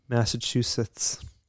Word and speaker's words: Massachusetts: Could you say Massachusetts again? – Massachusetts. – Thank you. Yeah. I have a pet peeve. – How should I say it? – No, you said it correctly, and okay. I thank you for that. – You're welcome. – I have a pet peeve that people who put a T in the middle of Massachusetts.